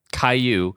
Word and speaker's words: Cayeux: It's spelled C-A-Y-E-U-X. How is that pronounced? Cayeux? Yeah, it's French. Yeah, that's the kid's show. – Cayeux? – Yeah. Cayeux.